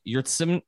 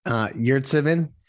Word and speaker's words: Yurtseven: Of course, the other thing was our friend Omer, I guess it's Omer Yurtseven. Pronounce it for me. Sorry. – Yurtseven. – Thank you. Much better than I did.